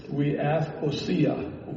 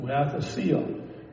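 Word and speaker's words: weathosia (υἱοθεσία): But the Greek word, the Greek pronunciation of the word is weathosia, weathosia.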